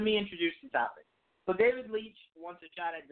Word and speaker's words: David Leitch: Yeah, it's David Leitch.